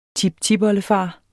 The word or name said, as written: tiptipoldefar